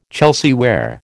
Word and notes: Chelsea ware: American English

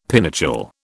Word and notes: pinochle: British English

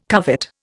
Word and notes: covet: Indian

